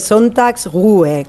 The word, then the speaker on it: Sonntagsruhe: A ver, el Sonntagsruhe, es una palabra difícil de pronunciar aún así, es Sonntagsruhe, que al final es descanso dominical, básicamente.